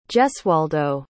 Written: Jesualdo

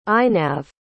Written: einav

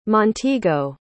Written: montego

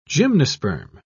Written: gymnosperm

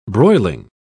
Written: broiling

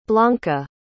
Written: blanca

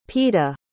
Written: peada

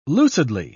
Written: lucidly

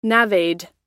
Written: navaid

